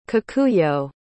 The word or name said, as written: kikuyo